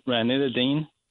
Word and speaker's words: ranitidine: And they said that they want to put her on ranitidine. Is that how I pronounce it? – Yes, it's an acid milligrams.